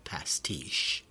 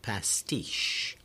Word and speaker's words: pastiche: Anyhow, the second syllable of that word, pastiche, T-I-C-H-E, is the one that's stressed, right? So, pastiche. English took the word from French, unchanged in spelling or meaning, and French actually adopted the word from Italian, pasticcio, pasticcio.